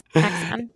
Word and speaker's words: Axion: Okay, so your company is devoted Axion, right? Is that how we pronounce your company name? – Yes, Axion. – It sounds exactly like a particle physics hypothetical dark matter candidate, but it's spelled differently.